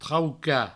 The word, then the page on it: traucar: trauca